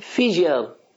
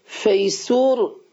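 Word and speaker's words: fissure: بعد به تلفظ انگلیسی چی میشه؟ اینال، اینال فیجر. باز اینجا توضیح دادم. خب اما این که تو پرانتز نوشته فیسور، این غلط، فیسور یه جیبیه.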